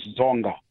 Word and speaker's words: Dzonga: And the second dialect is that of Xitsonga. Here, this one is found in area between Save. – Can you say that again, please, the second dialect? – Second dialect is Xitsonga. Xitsonga. – Xitsonga. And how would you spell that? – Xitsonga is D-Z-O-N-G-A.